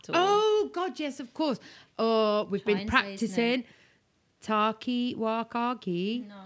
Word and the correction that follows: Taika Waititi: No. Taika Waititi. – Taika Waititi.